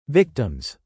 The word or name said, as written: victims